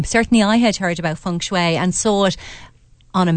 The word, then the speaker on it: feng shui: I hope I pronounced feng shui correctly. – You have, actually, Andrea. Well done.